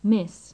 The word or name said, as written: miss